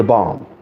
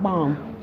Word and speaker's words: balm: And Christ is the balm. The balm, right? – Yes. – The healing balm. – Yes. Balm, not bomb.